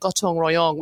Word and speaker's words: gotong royong: So, you know, there's this kind of wonderful notion, which you mentioned, and you perfectly pronounced it, gotong royong, which is to help your neighbor.